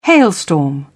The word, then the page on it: hailstorm: British English